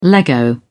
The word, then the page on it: lego: British English